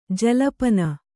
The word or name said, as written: ಜಲ್ಪನ